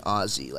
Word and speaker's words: Aussie: And he was like, like an Aussie, like with Z's. Yeah. Like, that's how they say it.